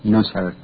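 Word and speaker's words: Neuser: It's N-E-U-S-E-R, Nusser, that's how I thought it should pronounce.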